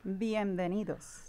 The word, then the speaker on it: bienvenidos: And it's bienvenido. How do you say that one? – Bienvenidos. – See, I was all wrong.